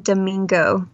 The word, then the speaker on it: Damigo: I'm pretty sure it's Nathan Damigo. – Damigo. – Damigo. – Damigo, yeah. – I don't think there's an N in there, actually. – Just Nathan Damigo.